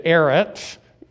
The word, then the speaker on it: eretz: Eretz is pronounced kind of like ferrets, but without the F there.